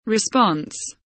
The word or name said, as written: response